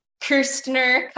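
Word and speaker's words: Kästner: A German novel and I'm going to slaughter this pronunciation, Das Doppelte Lochchen by Eric Kastner. There's an umlaut, so it's Kirstner, Kastner.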